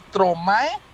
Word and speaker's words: Stromae: Mi hit de hoy suena así, suena, bueno, ahí va la pronunciación, es que yo no parlé francés, así que es Stromae.